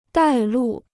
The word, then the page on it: 带路: dài lù